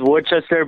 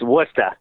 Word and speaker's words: Worcester: Everyone says Worcester, but it's Worcester.